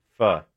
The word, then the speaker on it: pho: Pho. – Pho. So it should be P-H-U, like F-U-H, pho. – Yes, that's how you pronounce it.